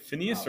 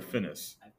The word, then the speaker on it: Finis: F-I-N-I-S. Is it Phineas or Finis? – It's probably Finis.